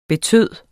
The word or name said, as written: betyde